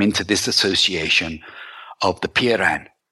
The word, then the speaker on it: Peiran: I'll spell that for you, Mel. P-E-I-R-A-N, Piran. And this association was pulled together of explorers,